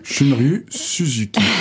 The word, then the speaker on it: Shunryu Suzuki: Shunryu Suzuki.